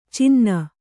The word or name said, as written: ಚಿನ್ನಿ